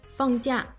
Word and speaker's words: 假: 读音念得很正确，放假念四声，表示可以有一段时间不工作或者不学习。